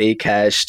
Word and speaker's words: Akash: Yeah, I used to pronounce it Akash too. Yeah, until I was corrected a few times. But yeah, it's Akash.